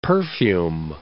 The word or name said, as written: perfume